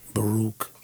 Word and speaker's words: Baruch: His name is like Barack. I'm sorry, Baruch.